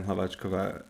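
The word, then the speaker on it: Hlaváčková: I used to get so annoyed when people would call Hradecka and Hlavačkova the silent H's because the H's are not silent in their names. Like, you say the H...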